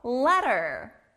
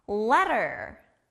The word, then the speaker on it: letter: 여기도 부드러운 T 소리가 나네요. 우리가 사랑하는 사람한테 정성스럽게 편지를 쓰죠. 편지, 영어로 letter. letter.